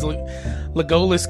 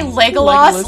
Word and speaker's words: Legolas: Here comes Henry Cavill wearing his Legolas cosplay. – Did you just say Legolas? You're putting the emphasis on the wrong syllable there.